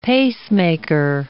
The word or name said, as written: pacemaker